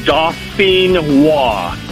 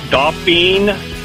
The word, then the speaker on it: Dauphinois: All right, so pronounce that for me one more time so I don't sound like a complete idiot when I order them. – No problem. It's, like I said, it's French. It's Dauphinois. And Dauphinois.